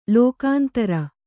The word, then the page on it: ಲೋಕಾಂತರ: kannada